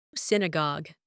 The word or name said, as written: synagogue